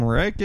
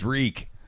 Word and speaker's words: Reek: And like, mom answers the phone, and she's like, Brenda, some guy named Rick is calling for you. – Oh, mom, it's Reek. – It's pronounced Reek.